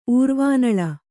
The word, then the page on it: ಊರ್ವಾನಳ: Kannada